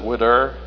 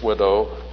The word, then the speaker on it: widow: We South Carolinians like to pronounce it widder, but it's widow.